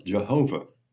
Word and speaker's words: Jehovah: And then when a mistake, when they came from German to English, they didn't know that a J in German is pronounced like a Y. And so they pronounced it now Jehovah. But Jehovah is really just a transliteration, an error, going from Yahweh to Jehovah.